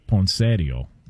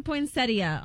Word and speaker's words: poinsettia: Well, the in-laws would call it poinsettio. – Oh, gosh. – Okay. What up? You know what I'm saying? Can we get the proper pronunciation on this? – It does say poinsettia online.